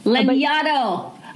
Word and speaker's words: Lanyadoo: Lanyadoo. – Yeah, it's nobody can pronounce it. It's not phonetic. It's not English. It's fine with me. But if you're going to butcher it, think of Xanadu.